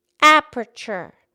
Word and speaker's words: aperture: Aperture. – Right. It's only three syllables. It's not aperture. It's just aperture.